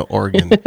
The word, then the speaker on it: Oregon: Did you say Oregon? No, Oregon. It's the Oregon Trail, as we pronounce it here.